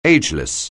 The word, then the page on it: ageless: anglais